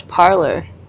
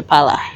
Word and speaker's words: parlor: As a British person, really parlor, P-A-L-O-R. – Yeah, right? – No, it's parlor. – P-A-R-L-O-R. – Yeah. – Okay, there you go. – And then we turn that into pala. – Pala. – Yeah. – Because we don't pronounce R's, really.